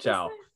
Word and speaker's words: Chow: Ciao. Ciao. Ah. like as in like chow mein or like you know, chow mein or clam chowder.